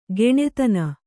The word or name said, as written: ಘನತನ